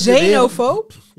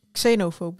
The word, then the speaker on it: xenofoob: Ja, wat jij zegt, maar dan correct uitgesproken. – Oh, oké. Okay. – Het is xenofoob, toch? – Nee, het is xenofoob. – Xenofoob is op zijn Engels.